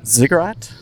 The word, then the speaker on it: Ziggurat: Why don't we talk about your first EP, Ziggurat? – Yes. – I said it right? – Mm-hmm.